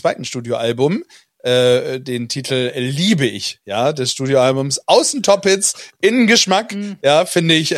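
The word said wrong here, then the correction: Außen Top Hits: Also die Betonung musst du vielleicht nochmal anders, sind Top-Hits. – Ja, Außen Top-Hits.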